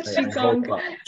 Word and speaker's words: Qigong: Qigong.